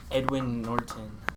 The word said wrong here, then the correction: Edward Norton: All right, pronounced Edward Norton. – Oh, that's what I said. It's my accent.